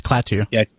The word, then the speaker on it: Klaatu: Is that how you say it? – Yeah, it's Klaatu.